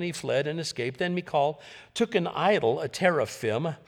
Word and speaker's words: Michal: But Michal, that's how it's pronounced in the Hebrew, David's wife, warned him, if you don't run for your life tonight, tomorrow you will be killed.